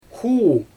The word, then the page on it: xuu: Xyzyl